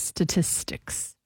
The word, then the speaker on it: statistics: Yeah, you didn't even say it right there just now. You said statistics. – I did not. I said it right. – There was no S in the beginning. You did not. – There was two. Statistics. – Oh, now you said it. You said it that time, but I've got it on tape.